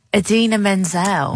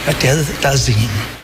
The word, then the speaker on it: Idina Menzel: How do you pronounce her name? Who sang that? – Idina Menzel. – Right, so not like... – Please welcome the wickedly talented one and only Adele Dazeem.